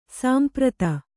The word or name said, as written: ಸಂಪ್ರೀತ